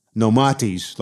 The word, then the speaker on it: Nomates: Yeah, it's pronounced Nomates, like Socrates.